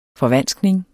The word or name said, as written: FORVANSKNINGERNES